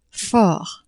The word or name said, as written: fort